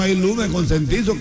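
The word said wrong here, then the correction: concientizo: Consentizo no se dice, se dice conscientizo.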